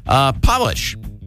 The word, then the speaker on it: polish: Polish, P-O-L-I-S-H. The word polish is the only word in the English language that changes its meaning and pronunciation when capitalized. Make that a capital P. It's Polish.